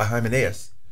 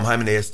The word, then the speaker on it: Hymenaeus: When I say Hymenaean heresy, I'm speaking of a heresy that was taught by Hymenaeus. Is how you need to say his name in Greek, but we call him Hymenaeus typically.